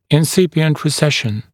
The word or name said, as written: incipient recession